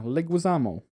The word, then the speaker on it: Leguizamo: Yeah, Leguizamo.